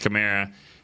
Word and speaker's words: Kamara: But the scary part about that to me is when you blitz and you think that I want to say Sproles. When you say that Kamara. I just read the pronunciation. – It is? – It's Kamara.